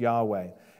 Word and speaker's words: Yahweh: Now, a lot of scholars, they say that the ancient Vav is pronounced like a W sound. So instead of saying Yahweh, they say Yahweh, okay? – I don't have a horse in that race, but it seems like that's the, that's a general consensus. There are some other views on that, Yehovah or Yehovah. I'm just going with the majority here, pronounce Yahweh.